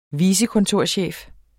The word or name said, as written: vicekontorchef